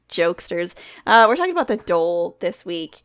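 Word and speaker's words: dhole: But it's pronounced Dole. – Dole.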